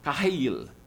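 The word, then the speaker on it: kahayil (חיל): The Hebrew word is kahayil. Kahayil. There you go. Kahayil. Two syllables.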